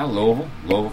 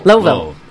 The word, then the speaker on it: Louisville: Louisville. So it's actually only two syllables, is what you're telling me.